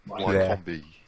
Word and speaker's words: Wycombe: We don't want to offend anyone from Wycombe.